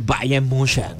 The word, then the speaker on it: Bayern München: Pero es porque juega en el Bayern München. – Excelente pronunciación.